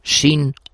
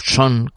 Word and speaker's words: Sean: Hemos dedicado estas dos partes del programa a un gran actor fallecido hace poco, Sean o Son, como quieran pronunciarlo, Connery.